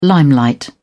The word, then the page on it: limelight: British English